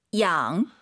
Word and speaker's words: 养: 양. – Y가 있으면은요 마음속으로 I로 바꾸세요. 이게 한글로 많은 분들이 양 이렇게 읽으시는데 정확한 발음은 이 양이에요. 그죠? – 네, 양. – 네, 이로 시작해야 돼요. 양이 아니에요. 양.